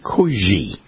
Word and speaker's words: koekje: And it's spelled K-O-E-K-J-E. Kuji.